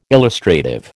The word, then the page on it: illustrative: American English